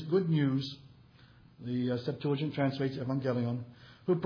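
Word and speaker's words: evangelion (εὐαγγέλιον): If you've been around too many preachers who went to seminary, you'll hear them say euangelion, but as Zach and his mother will attest, the proper pronunciation is evangelion.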